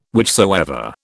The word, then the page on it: whichsoever: British English